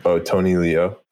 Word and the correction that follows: Leung: Tony Leung is what...